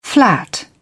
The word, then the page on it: flat: British English